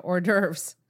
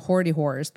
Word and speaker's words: hors d'oeuvres: When we worked at the banquet center, we always called them hoardy whores because people used to say it wrong because of the spelling.